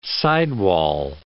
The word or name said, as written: sidewall